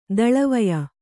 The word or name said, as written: ದಳವಾಯಿ